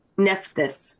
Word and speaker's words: Nephthys: Nephthys. – Okay. Let's look up how that's actually said on Google. Maybe they can help us say it phonetically. – Nephthys.